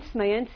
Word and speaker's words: Mayence: Mayence is the Hebrew pronunciation of the German city of Mainz. Mainz, M-A-I-N-Z, in its contemporary spelling, which was one of the well-established communities of Germany, of German Jews at that point.